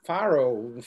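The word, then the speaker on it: Fårö: eu quero ir para Faro daí a mulher Farrow, Farrow da Fora, enfim, descobri como fala, pronuncia em sueco,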